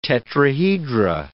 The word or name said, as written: tetrahedra